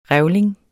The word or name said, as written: REVLING